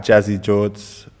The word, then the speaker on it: JazzyJordz: So it's at Jazzy Jords for me.